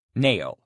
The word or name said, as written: nail